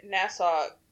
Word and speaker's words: Nassau: Because isn't it spelled like AU? – yeah, it's N A S S A U. Yeah. So I just wanted to make sure. I thought it was NASA. I didn't think there was any W or U on the end, but there is. So W in pronunciation, just one single U in writing.